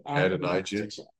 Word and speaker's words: Adonijah: Adonijah.